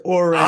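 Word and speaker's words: Ourand: He is the sports media reporter from the New York Post, has a great podcast, the Marchand and Uran podcast. Did I pronounce John's name correctly, by the way? – He did not. It's Oran.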